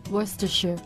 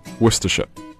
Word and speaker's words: Worcestershire: Worcestershire. – Yeah, that's pretty good. That's pretty good. It's better than Americans say it. They say Worcestershire. Bukerti.